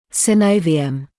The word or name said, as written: synovium